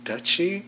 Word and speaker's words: Duchy: D-U-C-H-Y? – Duchy?